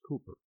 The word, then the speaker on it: Cowper: William Cooper. By the way, C-O-W-P-E-R, it's pronounced Cooper.